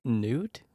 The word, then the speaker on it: Newt: Rhymes with toot. – What? – Newt? – Mm-hmm.